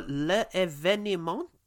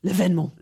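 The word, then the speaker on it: L'Événement: Or L'Evenement. – Actually, I think you don't even pronounce one of those E's properly. L'Evenement.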